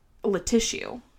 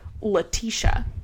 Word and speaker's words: Latissue: I, at my old job, there was someone with the name, and I'm going to spell it for you, L-A-T-I-S-S-U-E. – Huh? Latussie? – Letissue is how it's spelled. It's spelled like tissue. And my manager called her and said, is this Letissue? And she flipped on my manager because it's apparently pronounced Letitia.